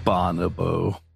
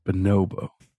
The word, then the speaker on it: bonobo: Bonobo. It's Bonobo.